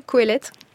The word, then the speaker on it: Kohelet: Je le prononce bien, Kohelet. – C'est ça.